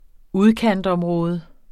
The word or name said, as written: udkantsområde